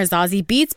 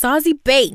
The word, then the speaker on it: Beetz: We've been calling her Zazie Beetz, but I think her name is pronounced Zazie Bates.